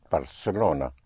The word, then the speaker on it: Barcelona: There's a slight difference in pronunciation with so-called Castilian Spanish in the pronunciation in parts of Spain, where the C in front of an E or I is pronounced like a TH in English, like in Barcelona. Well, that's how you will pronounce it in Castilian Spanish in parts of Spain, but everywhere else it will be pronounced Barcelona, like an S.